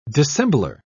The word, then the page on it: dissembler: British English